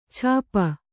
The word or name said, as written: ಛಾಪ